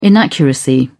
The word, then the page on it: inaccuracy: British English